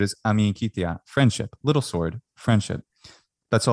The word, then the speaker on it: Amicitia: Amicitia is friendship, or amicitia in ecclesiastical pronunciation.